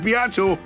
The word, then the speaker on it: Beato: It's not Beato, it's Beato.